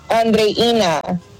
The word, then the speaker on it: Andreina: Okay, now this person's name is spelled like Adriana, but her phonetics are Audrina. Is that right? Are you Audrina? Is that right? – It's Andreina.